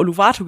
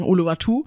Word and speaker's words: Uluwatu: Wir haben immer Uluwatu gesagt, aber ich glaube, die Einheimischen sagen Uluwatu.